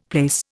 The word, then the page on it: place: Indian